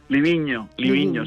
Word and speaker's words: Livigno: Livigno, sí.